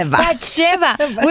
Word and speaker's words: Batsheva: Batsheva.